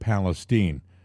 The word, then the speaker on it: Palestine: Now, people say East Palestine. Some say East Palestine. I would have said East Palestine, but I think the locals pronounce it Palestine.